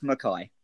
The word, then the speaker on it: McKay: Is it McKay or McKay? Because this could be quite Scottish, actually. Scott, how do you say your surname? – It's McKay. – McKay. – As in the prison governor from Porridge.